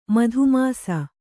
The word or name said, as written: ಮಧುಮಾಸ